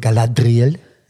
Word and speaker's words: Galadriel: Ähm, Galadriel, hier wird so ausgesprochen, Galadriel.